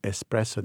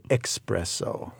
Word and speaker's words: espresso: Often you hear from American that they mispronounce the word espresso. They say espresso. – Right. – Okay. So even practicing a little yes. – bit. – Espresso. – Espresso.